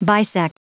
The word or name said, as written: bisect